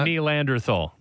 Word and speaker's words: Neanderthal: Is that how we're pronouncing that? – Neanderthal. Neanderthal. Yeah, that's an Anthony Stewart trademark, by the way.